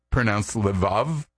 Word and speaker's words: levav: In Hebrew, the word heart is sometimes pronounced levav, or more often, it's in a shorter form called lev.